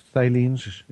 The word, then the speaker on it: phthalates: But these toxins that we're talking about, the plastic toxins, basically the category is called phthalates. Is that correct? – Phthalates. – Phthalates. – Phthalates. – And that's spelled with a P-H-T-A-L.